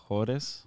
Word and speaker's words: Alfajores: How do you say them again? – Alpha Alpha Alfajores.